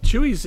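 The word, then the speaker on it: Chuy's: So Chewies is an absolutely classic, I believe it's out of Austin, Texas, restaurant, Tex-Mex restaurant. They have crazy interiors. It's spelled C-H-U-Y apostrophe S.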